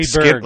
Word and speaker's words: Freeburg: Like Freeburg. – Like Skinnered? – B-U-R-G. – Yeah, that's what I said the first time. – Like Pittsburgh.